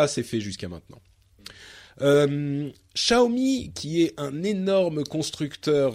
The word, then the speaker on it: Xiaomi: Euh, qui, et il y a des gens qui nous ont envoyé des enregistrements de euh, la manière dont on prononce Xiaomi. Alors, je vais essayer de reproduire. C'est Xiaomi. – Xiaomi.